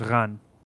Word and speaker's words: Rennes: Rennes. I think it's pronounced Ran in French.